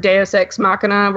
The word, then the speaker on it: deus ex machina: But, how do you say that stupid word, deus ex machina, where you just kill her? I'm saying it wrong. I'm probably saying it the Dylan way, which is fine. – You got it right. Deus ex machina. That's the Greek.